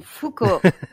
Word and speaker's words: Foucault: Uh, I believe and, it's uh, pronounced Foucault. No, Foucault. Foucault.